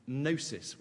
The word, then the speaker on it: gnosis: They're very keen on knowledge in Corinth, on gnosis with a silent G.